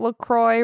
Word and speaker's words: LaCroix: Some people say LaCroix, but it says on their website, LaCroix rhymes with enjoy. Unless you say enjoy, enjoie, then you're saying it wrong.